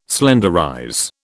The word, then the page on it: slenderize: British English